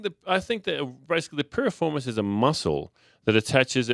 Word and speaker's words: piriformis: 10 piriformis stretches. I'm not sure I'm saying that correctly. – Piriformis stretches.